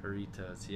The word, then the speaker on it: Hornitos: Hornitos, yeah.